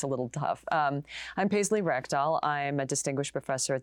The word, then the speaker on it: Rekdal: Paisley Rekdal.